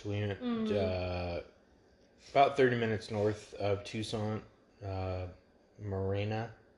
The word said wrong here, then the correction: Marana: I think they pronounced it Marana.